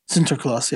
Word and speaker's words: Sinterklaas: So for those who are curious about if you need to worry about Sinterklaas, is that how you say it? – Sinterklaas, yeah.